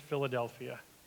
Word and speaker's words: Philadelphia: And it is 100% okay to just pronounce it Philadelphia.